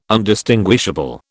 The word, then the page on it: Undistinguishable: British English